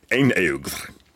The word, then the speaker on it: einaugr: The word is Einaugr, meaning one-eyed. It's pretty cognate with the English phrase and sounds close enough to it if you kind of squint your ears a little bit.